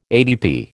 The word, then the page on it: ADP: American English